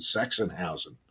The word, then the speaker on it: Sachsenhausen: at Sachsenhausen concentration camp near Berlin. – Well pronounced, by the way. Well done.